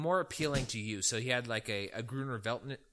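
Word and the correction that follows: Grüner Veltliner: Is that, am I pronouncing that correctly? It's a white German varietal. – You might be, but I might not be thinking of the right one. – It might be Gruner Veltliner. That might be the correct pronunciation.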